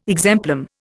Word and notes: exemplum: Indian

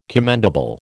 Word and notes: commendable: American English

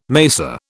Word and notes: macer: British English

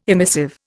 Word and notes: emissive: Indian

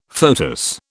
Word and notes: foetus: British English

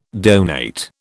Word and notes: donate: British English

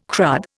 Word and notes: crud: Indian

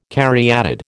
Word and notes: caryatid: American English

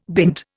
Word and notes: bint: Indian

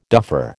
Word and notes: duffer: American English

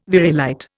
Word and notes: variolite: Indian

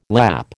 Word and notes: Lapp: American English

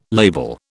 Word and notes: label: British English

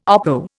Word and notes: oppo: Indian

